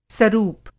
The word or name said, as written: सरूप